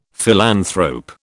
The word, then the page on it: philanthrope: British English